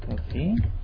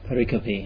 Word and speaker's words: pericope: I believe the pronunciation is. Fortunately, Adi is not here to correct me. Pericope. I believe only barbarians pronounce it pericope. Okay. And like I used to, until I heard Rod pronouncing it pericope.